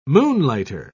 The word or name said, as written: moonlighter